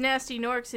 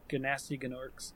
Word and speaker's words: Gnasty Gnorks: They're they're pronounced as nasty Norks in Reignited. – Nice. – I think in the original one they said Ganasty though. – Ganasty. – Ganasty Ganorks.